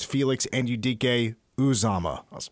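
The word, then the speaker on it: Anudike-Uzomah: Until it was before the last game, his final season, and I asked him, like, hey, am I saying your name right? Felix Anudike Uzomah. He said it's Felix Anudike Uzomah.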